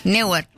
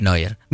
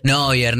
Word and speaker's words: Neuer: Me acabo de dar cuenta que es el arquero que yo pronuncié muy mal. – Neuer. – Neuer. Y yo dije Neuer. Sí. Y es Neuer. – Neuer.